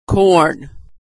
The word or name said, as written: corn